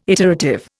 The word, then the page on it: iterative: Indian